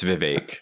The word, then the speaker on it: Vivek: I know that he said, I listened to his rap and his name rhymes with cake. So it's Vivek.